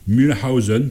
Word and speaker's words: Mühlhausen: Alors nous avons là une des premières cantates que Bach a composées quand il était organiste à Mühlhausen. Peut-être que je ne prononce pas bien, on m'excusera, j'espère. – Non, c'est bien.